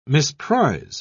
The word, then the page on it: misprize: British English